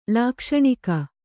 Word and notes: ಲಾಕ್ಷಣಿಕ: lākṣaṇika

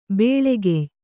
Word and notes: ಬೆಳಿಗ್ಯೆ: beḷigye; Kannada

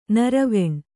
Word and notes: ನಿರ್ವಾಣಿ: nirvāṇi

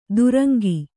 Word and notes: ತುರಂಗಿ: turaŋgi

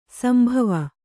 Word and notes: ಶಂಭವ: śambhava

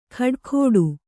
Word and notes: ಖಡಾಖೋಡು: khaḍākhōḍu

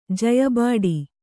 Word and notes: ಜಯಬಾಡಿ: Kannada

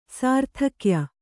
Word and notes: ಸಾರ್ಥಕ್ಯ: Kannada

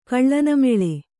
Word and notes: ಕಳ್ಳನಮೆಳೆ: Kannada

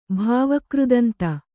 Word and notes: ಭಾವಕೃದಂತ: Kannada

